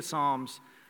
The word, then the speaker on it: Psalms: It's counterintuitive, but it doesn't start with an S. It starts with a P. So when you're looking for the book of Psalms, it starts with a P. It's a silent P at the beginning of the word, and it's also in the middle.